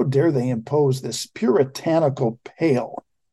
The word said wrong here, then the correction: pall: Or Paul, I'm sorry, it's pronounced Paul, not pale.